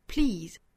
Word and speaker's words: please: 最后一句 Please don't take my sunshine away，需要注意的点呢，首先第一个词就是 please 这个词，注意到里面有一个辅音连缀哈，就是 p 和 l 两个辅音连缀在一起，我们要读 p l pl 大家不要读成泼了，一定是非常短非常快的过渡到后面元音，在辅音之间呢不要加音不要停留，是 pl please please，不要读成了 please，那应该是 please please。